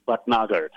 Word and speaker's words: Bhatnagar: So my name is Vic, and Rob, you did a pretty good job of my last name, but actually it's Vic Bhatnagar. So I know it's hard to pronounce, but I'll forgive you for this one time.